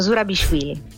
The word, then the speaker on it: Zurabishvili: Peraltro oggi sul Foglio c'è una sua intervista alla presidente della Georgia, Salomè Zurabishvili, cioè ci facciamo aiutare da lei a pronunciarla perfettamente, Micol Flammini, giornalista del Foglio. Ciao Micol. – Ciao, buongiorno, hai pronunciato benissimo, Zurabishvili.